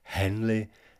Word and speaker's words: heavenly: And here, heavenly is pronounced as two syllables, henly, and never as one, ne'er, in order, as so very often, for the lines to scan.